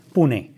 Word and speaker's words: Pune: Pune.